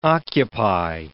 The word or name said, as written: occupy